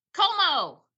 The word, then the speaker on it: Comeau: Comeau.